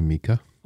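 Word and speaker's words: Mica: It's M-I-C-A. Would you pronounce that Mika or Micah? – I'd say Mika.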